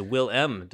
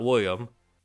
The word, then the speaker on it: Willem: on it saying, quote, I'm way too late, and you just keep saying it wrong, but it is Will-M Dafoe, not William.